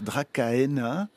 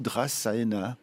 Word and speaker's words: Dracaena: Alors, on peut dire Dracaena, ouais. Ou Drasaena. Voilà. En principe, voilà. En latin, on prononce toutes les lettres comme en allemand.